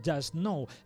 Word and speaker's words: Jusk-No: Hoy un dúo llamado Just Know, aunque se escribe Jusk-No.